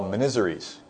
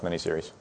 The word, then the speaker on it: miniseries: referring to the Civil War miniserie that was out there, that was in fact pronounced miniseries.